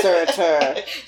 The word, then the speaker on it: Surtur: No, it's definitely Surtur. – Surtur. – Because it's very specifically Surtur. Like, it's almost like two words, but you have to string them together.